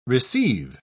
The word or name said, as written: receive